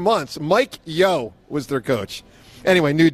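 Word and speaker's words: Mike Yeo: I actually thought you said it right. You said Mike Yo. – Isn't it Mike Yo? Yeah, Mike Yo.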